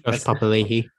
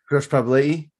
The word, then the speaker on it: Papali'i: I don't know what they're trying to do with, I don't know what Billy's doing with Papali'i or how do I say his name again? Papali and Papali'i? – Josh Papali'i. – Josh Papali'i.